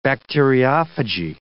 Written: bacteriophagy

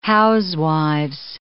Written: housewives

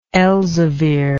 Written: elzevirian

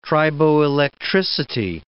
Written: triboelectricity

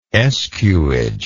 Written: escuage

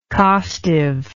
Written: costiveness

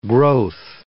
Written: growth

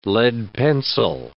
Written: lead pencil

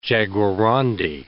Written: jaguarondi

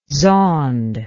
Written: zond

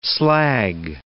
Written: slag

